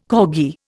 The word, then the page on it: corgi: Indian